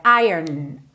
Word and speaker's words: iron: Pois é, a palavra iron, que é o ferro em inglês, I-R-O-N, ela não é pronunciada como a gente imagina ao ver a palavra escrita, certo? A gente tende a pensar em iron, right? Só que esse O, na verdade, não é pronunciado. Então é como se a gente fizesse ir e já fosse direto pro N.